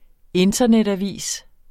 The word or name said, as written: INTERNETAVIS